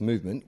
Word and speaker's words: MVMT: Introduction, MVMT Watches, pronounced Movement, was founded on the belief that style shouldn't break the bank.